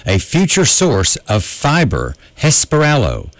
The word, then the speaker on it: Hesperaloe: It's a plant called Hesperalo, H-E-S-P-E-R-A-L-O-E, pronounced Hesperalo.